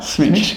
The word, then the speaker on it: Smitch: Smitch, yep. – Rhymes with itch.